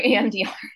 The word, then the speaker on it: EMDR: EM, is it EN or EMDR? Yeah. – You're right, EMDR.